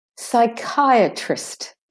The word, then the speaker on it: psychiatrist: Psychiatrist. Trist. The second I is de-stressed. Psychiatrist. The A is de-stressed.